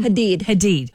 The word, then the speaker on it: Hadid: For controversial almond moms like supermodel turned reality star Yolanda, how do you say her last name? – Hadid.